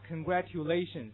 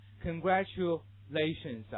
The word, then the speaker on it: congratulations: 比如说你们比较熟的经常听到啊，某人这么说，你当你获得什么荣誉啊，或者碰到什么好事了，有人祝贺你说这样一个单词啊，你永远听不到这个人读什么 congratulations，那是你同学的读法。如果是老外对你这么说，那会肯定会读成什么 congratulations 啊，注意发生这样音变，单词内部 congratulations。